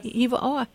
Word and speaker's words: Hiva Oa: Hiva Oa.